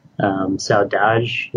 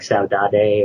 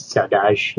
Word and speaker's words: saudade: Her name, Sadie, is actually short for Saudade, is how it's pronounced. It looks like Saudade or Saudade, but it's Saudade is how it's pronounced.